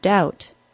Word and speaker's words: doubt: Your first lesson of this podcast, then, is that you can be confident in dropping the B sound from the words subtle, debt, and doubt. In fact, pronouncing the B sound is wholly incorrect.